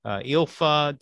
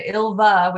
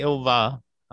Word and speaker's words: Ilva: Mike used to call her Ilfa, and Ilva with a V is how it's correctly pronounced, but she will respond to Ilfa as well. – Okay, Ilva.